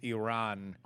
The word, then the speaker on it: Iran: It's Iran. – Iran? – Not Iran.